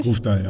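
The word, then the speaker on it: Grøvdal: Ich glaube, Grofdal spricht man so aus, richtig? – Grofdal, ja.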